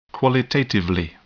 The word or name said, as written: qualitatively